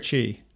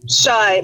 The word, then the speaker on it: Chai: So is your husband Chai? Is that how you say it? Is it Chai or Chi? – Shy, like the personality trait.